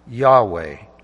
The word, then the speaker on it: Yahweh: It is actually a four-letter word in Hebrew spelled Yod, He, Vav, He, or loosely in English, YHWH, sometimes pronounced Yahweh.